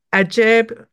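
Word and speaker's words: Ajaib: And I also noticed there were a ton of investment apps that got attention, like Pingdu, Bbit, Ajib. I don't know if I'm pronouncing that correctly. – Yeah, that's right.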